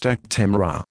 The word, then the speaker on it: Actemra: This is the consumer medicine information for Actemra and Actemra SC pre-filled pen, Actpen, pronounced Actemra.